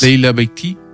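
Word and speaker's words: Leïla Bekhti: Alors, c'est je sais jamais comment on prononce réellement, mais c'est Leïla Beïti, je crois que ça se prononce. – Leïla Beïti. – Beïti, voilà.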